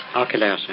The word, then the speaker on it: Archelaus: how do you say that, Archelaus? – Archelaus.